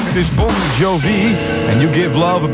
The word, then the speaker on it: Bon Jovi: Maar de officiële uitspraak is dus Bon Jovi.